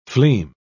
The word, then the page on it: fleam: British English